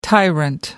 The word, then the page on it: tyrant: American English